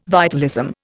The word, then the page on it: vitalism: Indian